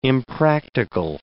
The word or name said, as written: impractical